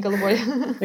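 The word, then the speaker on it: Goldoboy (голубой): Sini and Goldoboy. I'm probably pronouncing those wrong. Forgive me. – No, that's fine. Sini, Goldoboy.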